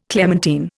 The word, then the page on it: clementine: Indian